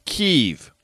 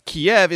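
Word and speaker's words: Kyiv: Then in 2014, when there is this invasion in Crimea, the Obama White House goes to a very particular pronunciation, Kiev. And I asked, what is that about? And they said, well, that's the Ukrainian preferred pronunciation. Kiev is the Russian preferred pronunciation.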